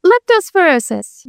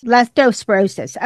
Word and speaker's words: Leptospirosis: Leptospirosis. – Leptospirosis.